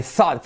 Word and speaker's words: thought: Fíjate cómo se pronuncia, thought, thought. Z, sonido TH, letra semiclave. Z, sonido Z, como zapato en España, Zaragoza, thought, ¿vale? Y fíjate cómo te digo que se pronuncia TH, o se escribe T-H-O-U-G-H-T.